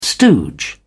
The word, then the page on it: stooge: British English